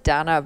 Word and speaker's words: Dana: I pronounce it Dana, but when I speak to Americans, it's like Dana, D-A-N-A James.